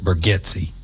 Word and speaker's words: Bargatze: And that is how you pronounce his name. It's spelled Bargatze, but he pronounces it Bargatze.